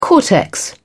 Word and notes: cortex: British English

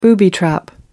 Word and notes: booby-trap: American English

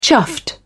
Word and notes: chuffed: British English

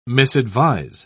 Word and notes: misadvise: British English

